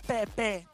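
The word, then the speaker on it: Pepé: Pepe. – Ah, ok, con el acento en la ok, en la última.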